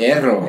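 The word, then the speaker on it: Herro: Parece Erro.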